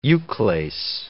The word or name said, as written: euclase